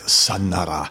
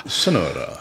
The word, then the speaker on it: Sonora: I'm pronouncing it Sonora. – Sonora.